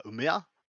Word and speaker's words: Umeå: I actually had to look up uh, it's uh, Umeå. Uh, I had to actually look up how to pronounce it because I, was, I, was, I didn't want to do it wrong.